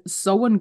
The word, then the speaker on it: Samhain: Weil als ich das erste Mal Sowen gehört habe, hing ich da, was will die von mir? Was ist das für ein Fest? Kenne ich nicht. Ah, Samhain, okay.